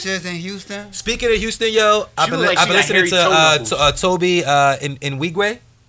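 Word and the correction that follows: Tobe Nwigwe: Toby Nwigwe? – Yeah, I ain't know how to pronounce it.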